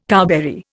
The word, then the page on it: cowberry: Indian